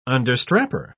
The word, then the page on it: understrapper: British English